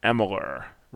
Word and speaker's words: Amalur: Moving on though, Kingdoms of Amalur: Reckoning. Did I say that correctly? – I believe so. – Yeah, that's how Kurt Schilling says it. That's all that matters.